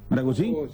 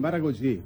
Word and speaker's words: Maragogi: En Brasil, Maragogi. – Mira, usted queda, mira el portugués, mira, mira. – Mira qué hermoso. – Está bien pronunciado, ¿no? ¿Maragogi? – Sí, Maragogi.